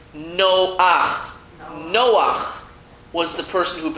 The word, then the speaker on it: Noach: And the reason is because if you look at his name, and we know it's Noach, that's how it's said, but look at the way it's spelled.